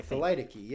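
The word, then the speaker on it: thelytoky: T-H-E-L-Y-T-O. – T-H-E-L-E-O. Wait. T-H-E-L-Y-E-O? – T-O-K-Y. T-H-E-L-Y-T-O-K-Y. Thelitiki. Thalaitiki. – Oh, Thalaitiki, yeah.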